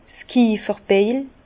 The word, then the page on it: 's Kiefferbeil: Bas-Rhin; Herrlisheim